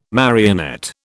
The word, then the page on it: marionette: British English